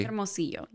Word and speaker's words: Hermosillo: Hermosillo. – Mm-hmm. – Am I pronouncing it correctly? – Hermosillo, yes.